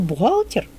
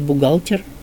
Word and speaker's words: бухгалтер: Еще у нас есть слово «бухгалтер», которое нужно с гофрикативным, но там совершенно по-другому. Это, конечно, не церковно-славянизм, а немецкое слово. И там как-то «бух» и «г» соединяются, они вместе дают вот это гофрикативное.